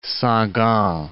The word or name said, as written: Sagan